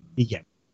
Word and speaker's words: Ige: Ige.